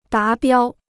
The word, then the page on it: 达标: dá biāo